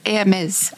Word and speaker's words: Hermes: It's Hermes.